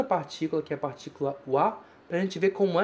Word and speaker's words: wa: Wa tsuri o shimasu. Muito cuidado com esse wa, porque aqui ele é escrito ha, mas é pronunciado wa.